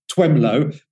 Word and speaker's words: Twemlow: And it sounds like you've got a bit of a speech impediment when you say his name, Twemlow, but it is actually spelled like that, T-W-E-M-L-O-W, Twemlow.